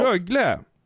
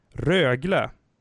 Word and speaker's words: Rögle: Rögle! Så där uttalar man det faktiskt inte, har jag fått lära mig av Johan Svensson och Sanne Lindström, mina kära kollegor och vänner. Rögle, Växjö, Luleå, Skellefteå, Leksand, Frölunda, Örebro, Färjestad, Malmö, Djurgården, Brynäs, Oskarshamn, HV71, LHC.